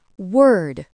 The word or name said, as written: word